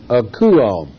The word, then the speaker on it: akouo: Now, this is the word for hearken, A-K-O-U-O. It's pronounced akuo.